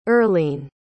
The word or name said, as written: Erlene